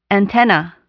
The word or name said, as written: antenna